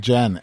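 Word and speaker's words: NGen: Angen. – Angen. – N as in letter N. – Oh, Angen. – And then G-E-N.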